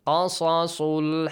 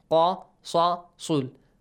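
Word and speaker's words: القصص: Qasasul haqq. Qasasul. All of them should be pronounced with heavy sounds, yes? Qasasul. Not qasasul, no.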